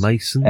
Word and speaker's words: Machon: So we've got one person making their 7th appearance, which is Fred Machon. – Or Machon, I'm not sure how it's pronounced. – Mason. – M-A-C-H-O-N.